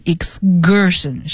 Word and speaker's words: excursion: 那么这个单词呢，有同学说是不是应该念 excursion 呢，这个 C 发科的音呢、好，其实字典上会这样写，但是因为发在 S 清辅音后面重读的这种音都要浊化，所以呢就念成 excursion，是 G 像 guard 这种浊辅音。